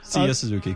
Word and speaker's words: Seiya Suzuki: You want to start with Seiya Suzuki? – Yeah, we could start there with him. – Am I saying that correctly, by the way? I am, right? – Seiya Suzuki.